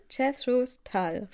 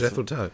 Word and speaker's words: Jethro Tull: Warte, Jethro Tull, richtig? – Genau. Das, Jethro Tull, ja.